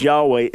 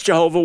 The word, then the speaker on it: Yahweh: The pronunciation of Yahweh, Yud-Heh-Vav-Heh, as Yahweh, is a scholarly guess. You say, oh, no, but we know it's Jehovah. Why guess? No, we know it's not Jehovah, overwhelmingly.